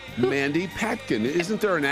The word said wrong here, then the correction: Patinkin: M-A-N-D-Y. – Mandy. – It's Patinkin. – Yeah. – Not Mandy Patkin.